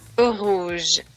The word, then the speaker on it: Rouge: O bueno, déjenme lo digo bien. Rouge.